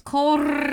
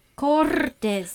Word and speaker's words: Cortez: You didn't roll your R. – Cortez. – No, it's Cortez. – I can't do the rolling R's thing. – Cortez.